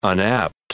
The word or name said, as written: unapt